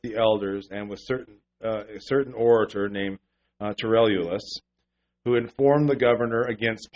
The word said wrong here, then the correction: Tertullus: And when he was called forth, I'm not pronouncing the T. Tertullus.